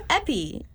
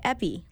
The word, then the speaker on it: Epi: All right, and I'm Epi. Yes, I know. I mean, Epsiba is my real name. It's very hard to pronounce, so I just go by Epi, like an EpiPen.